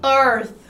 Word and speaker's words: Earth: Earth, Earth. Earth, tá bom? É um Fzinho com a língua presa assim, então Earth tá vendo?